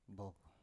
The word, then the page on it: bov: Biak